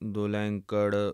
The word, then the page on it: dulankar: Punjabi